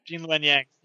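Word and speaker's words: Gene Luen Yang: But I'm hearing that you need to read, you're going to have to help me say the name right, Jing Luen Yang's essay. – Jing Luen Yang.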